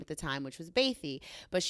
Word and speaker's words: Bathé: That's actually the pronunciation is Bathé.